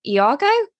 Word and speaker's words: Iago: But um, kind of linking how she is with so old to Elliot, I put um Iago.